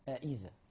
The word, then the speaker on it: أئذا: بطريقة أوضح وأسهل النطق بالهمزتين، حضرتك تنطق الهمزتين أئذا أئذا.